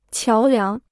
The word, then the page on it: 桥梁: qiáo liáng